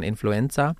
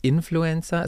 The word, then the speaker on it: Influencer: Meine Mama zum Beispiel sagt immer nur ganz gern Influencer. Sage ich, nein, das heißt Influencer. Es kommt auf die Betonung an.